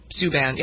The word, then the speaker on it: Subban: Is that how you pronounce it? Subban? – Subban, yep.